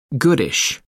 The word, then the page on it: goodish: American English